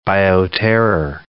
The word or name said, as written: bioterror